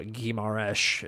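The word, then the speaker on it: Guimarães: Bruno Guimaraes is actually how that is pronounced now that I've spent the Portuguese time to learn the pronunciation. I hate the sh at the end, even though it's an S.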